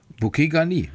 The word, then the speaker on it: bouquet garni: Bouquet Garni, würde ich sagen. – Ach, Garni, also mit, mit ja. so langem I hinten.